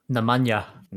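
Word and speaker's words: Nemanja: how do you pronounce his first name? Namaja? – Namanya. – Namanya.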